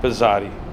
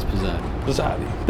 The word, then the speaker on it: Pezzati: It should be said like pizza, with a TZ, but Americans say it Pezzati. – Nice. – So it's Pezzati.